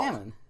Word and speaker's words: salmon: And how do we say the leaping red fish? – Salmon? – Yeah, no L. – Salmon.